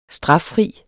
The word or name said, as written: straffri